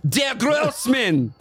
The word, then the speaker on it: Der Grossmann: You said Der. – That's how it's spelled. Der Grossmann. It says Der Grossmann. I'm sorry. I'll go back. Der Grossmann. – Thank you.